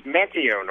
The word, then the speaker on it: Manteo: Actually, it's Manteo, North Carolina.